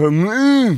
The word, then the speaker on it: Hormuz: Yeah, yeah, just take a punt going through the Strait of Hormuz. – Yeah. Say it correctly. – And then this is the maturity we're after. Strait of Hormuz.